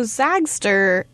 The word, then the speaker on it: Zagster: So Zagster is, that's how you say it, right? – Mm-hmm.